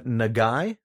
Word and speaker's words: Nagai: His name was Quince, not related to the fruit of the same name, but he was a Nagai, I believe it's pronounced. – Yeah, that's how it's pronounced. – N-A-G-A-I.